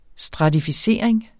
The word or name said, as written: stratificering